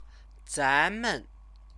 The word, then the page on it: zanmen: chinese